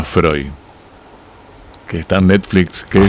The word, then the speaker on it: Freud: Pero se escribe Freud, Freud o Freud. Freud, como Sigmund Freud.